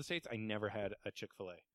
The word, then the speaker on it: Chick-fil-A: Chick-fil-A. That's how it's pronounced.